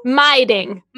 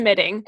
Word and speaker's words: midding: I believe it's pronounced midding. – Miding. – Miding? – No. I think it's midding. – It's midding. It's spelled MIDDING. If anyone wanted to know.